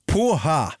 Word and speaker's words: porra: säger porra. Porra uttalas Poha och betyder fritt översatt sperma ungefär och det är en väldigt populär svordom i Brasilien.